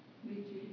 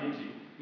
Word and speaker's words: Nietzsche: Now, on the other hand, German philosopher and atheist, I'm going to say it wrong, one of you going to tell me, Friedrich Nietzsche? – Nietzsche. – All right, Nietzsche.